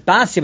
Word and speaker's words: Bisya: And Ve'elu b'nei Bisya, these are the child of Bisya, also otherwise known as Basya, but it's pronounced Bisya here.